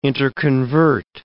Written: interconvert

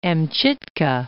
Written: Amchitka

